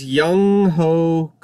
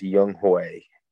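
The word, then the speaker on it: Younghoe: Deontay Johnson versus Young Ho Koo. – Young Hui. His name is pronounced Young Hui Koo.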